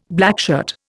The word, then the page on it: blackshirt: Indian